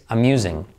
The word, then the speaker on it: amusing: pero esta es un poco distinta que es amusing. Amusing, amusing, amusing, entretenido, divertido. ¿ok? Se pronuncia como decimos amusing, amusing, alright? Fíjate, esa S es amusing, alright?